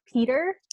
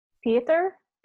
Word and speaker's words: Pietyr: But, like, so the character's name, I think, is pronounced Peter, but it's not spelled normally like Peter. It's hold on, let me find it. P-I-E-T-Y-R. So I have been pronouncing it like Pieter or something like that. Oh. No, I think it's just Peter.